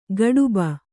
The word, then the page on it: ಗಡುಬ: gaḍuba